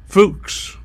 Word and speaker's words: Fuchs: It's spelled F-U-C-H, pronounced Fuchs. Hope I got that right.